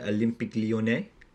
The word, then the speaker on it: Olympique Lyonnais: Damo, help me out with the pronunciation here, but Olympique Lyonnais. – Yeah, Olympique de Lyonnais. – Yeah, very nice.